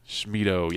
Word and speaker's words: Schmitto: Schmido. – Yeah, I knew that. – How do you spell it? – S-C-H-M-I-T-T-O.